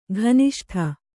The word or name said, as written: ಘನಿಷ್ಠ